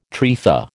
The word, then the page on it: trefa: American English